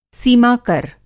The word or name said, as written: सीमा-कर